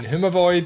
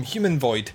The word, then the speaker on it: Humavoid: Happy that Dave has mentioned the name as being Humavoid, because I was going to call them Human Void, which is not how it's spelled either. So Dave is right and I'm wrong.